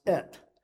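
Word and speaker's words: Et (את): It's interesting in Hebrew, you have this little thing with the Hebrew letters Aleph Tav, and it's pronounced Et.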